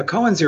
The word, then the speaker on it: koan: I don't know how to pronounce it, but it's spelled K-O-A-N, Koans? – Koan, yeah, Koan, sure. – Yeah, we've been reading a couple of those that we found, and I'm like, well, these are interesting. – Yeah, koans are interesting because referring to what you just said about mind coming from nowhere, koans show you that when you solve them.